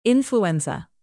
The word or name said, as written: influenza